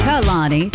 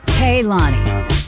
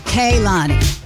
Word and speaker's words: Kehlani: It's not Kehlani, like Kehlonic, and it's not Kehlani, it's Kehlani. K, like K-A-Y, but spelled K-E-H. Kehlani.